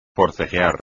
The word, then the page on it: forcejear: espagnol